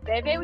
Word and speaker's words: vehvi: Especially that silent H, which is often a thing like you, because you have especially the word veve, which is spelled V-E-H-V-I. It's that same combination of V vowel H with the harsh V sound. That's a really specific thing to this Gerudo language.